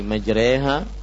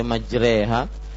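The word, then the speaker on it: majreha (مجراها): Beliau yang lebih mempunyai kapabilitas kapasitas menjawab permasalahan ini tapi yang saya ketahui bahwa pengucapan bismillahi majreha mursaha kemudian juga ada uh, dari lafat-lafat yang lain padahal tulisannya majroha kenapa dibaca majreha ini semua adalah riwayat Hafs dari Asim jadi memang Eh,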